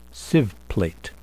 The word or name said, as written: sieve plate